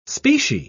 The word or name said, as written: specie